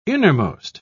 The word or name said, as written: innermost